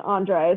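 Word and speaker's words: André: Like, I can't roll my R's. Andre is how I'm gonna call him. Just like the champagne.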